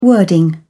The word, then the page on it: wording: British English